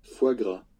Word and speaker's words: foie gras: Okay, so we've probably all heard of, how do you pronounce it? Foie gras? Foie gras? – Foie gras. – Foie gras. – Foie gras. – Yeah, what they said.